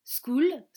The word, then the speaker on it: school: School se prononce school comme cool.